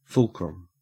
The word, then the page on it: fulcrum: Australian English